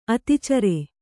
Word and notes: ಅತಿಚರೆ: ati care

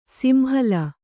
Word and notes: ಸಿಂಹಲ: simhala

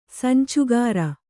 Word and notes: ಸಂಚುಕಾರ: Kannada

